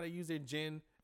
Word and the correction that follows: Nen: Did I say that right? Their gen. – Yeah. – Nen. – And their nen. They're using how to use their nen.